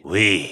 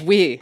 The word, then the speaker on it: oui: But what I did was I took, you know, those, I don't know how you pronounce it, but oui, or that French yogurt. – Oui. – Oui. – It's oui. – Oh, okay. – It's oui. – It's spelled oui, but it's oui.